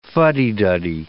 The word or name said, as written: fuddy-duddy